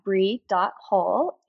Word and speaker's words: Bri Hull: So I am on TikTok and Instagram at Brie.Hull, H-U-L-L. And I guess my first name is spelled B-R-I.